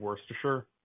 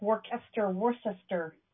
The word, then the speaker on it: Worcestershire: Yeah, did I nail the pronunciation of Worcestershire? – Yeah, yes. My other reader did not. He was like, Worcester. No.